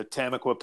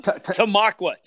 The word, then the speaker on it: Tamaqua: Well, if you're gonna race a hard enduro, I highly recommend going to Tamaqua, Pennsylvania. Did I say it right? – No, you butcher it every single time. – Tamaqua. – Is it Tamaqua?